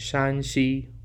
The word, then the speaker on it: Shaanxi: It might be difficult to hear the difference, and that's because the only difference between the pronunciation of these two provinces is the tone. 2 weeks ago, we highlighted Shaanxi with a rising tone for Shan, and this week we're looking at Shaanxi with a flat tone for Shan.